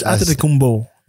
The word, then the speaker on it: Antetokounmpo: Antetokounmpo. Boom. As we learned with Yannick. – Antetokounmpo. It's a B sound, but.